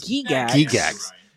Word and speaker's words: Gygax: So D&D was originally designed by Gary Gygax, which I found out that's how you pronounce it. – Gygax. – Gary Gygax.